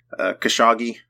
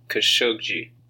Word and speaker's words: Khashoggi: They pronounced it Jamal Khashoggi. – Khashoggi. – The last G is like a J. – Okay. – The first G is pronounced like a regular G.